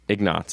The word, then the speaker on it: Ignatz: Ignatz is how they pronounce it.